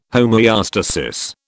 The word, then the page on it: homoeostasis: British English